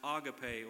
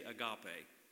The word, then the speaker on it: agape: Now, I've heard that pronounced agape. Over in Wayne County, we say agape. So I'm not sure how you folks do it over here, but that's what we say.